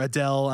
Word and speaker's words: Adel: So basically, There was during the time of the Sorceress Wars, which was like decades ago, Sorceress Adel or Adele. I don't know how you say it. – A-D-E-L-L. – Yeah. No, it's just A-D-L. A-D-E-L. – Oh, okay. Yeah. – Adel. We always just said Adel, I guess.